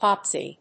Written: popsie

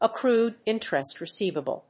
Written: accrued interest receivable

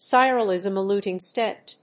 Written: sirolimus-eluting stent

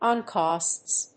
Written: oncosts

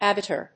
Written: abitur

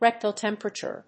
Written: rectal temperature

